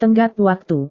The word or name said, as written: tenggat waktu